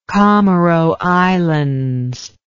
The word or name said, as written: comoro islands